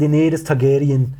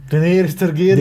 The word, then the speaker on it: Daenerys Targaryen: Er sagt Daenerys Targaryen. Daenerys Targaryen. – Daenerys mit so mit gerolltem R und ähm, Äh, äh, er sagt halt auf.